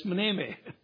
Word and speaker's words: mneme: It's not mimi, it's mneme. M-N-E-E-M-E-E.